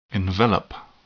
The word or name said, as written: envelop